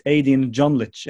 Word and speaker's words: Ejdin Djonlic: So it's Ejdin Djonlic.